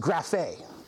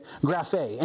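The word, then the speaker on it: graphe (γραφή): It's pronounced graphe. Okay, graphe.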